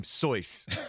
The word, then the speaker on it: Seuss: And also, Dr. Seuss pronounced his name Soyce. Dr. Soyce.